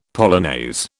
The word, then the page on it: polonaise: British English